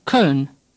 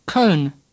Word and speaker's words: Köln: Now we have an O with two dots, pronounced er, somewhat similar to our sir or colonel. Say sir. Say colonel. Now say Köln.